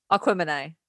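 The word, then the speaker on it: Aquemini: Aquamany. Like Aquarius, Gemini. Aquamany.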